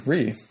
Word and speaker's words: Ryu: Very similar inputs to a Ryu. Ryu. That's what they're. Ryu. That's how they say it in game as well.